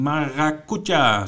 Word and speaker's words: Maracuja: Uh, eh, Capeland eh, en Maracuja, zo moet ik het uitspreken.